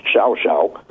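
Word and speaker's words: Llao Llao: I don't know how they get that pronunciation, but it's LLAO-LLAO, pronounced Xiao Xiao.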